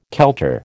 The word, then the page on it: kelter: American English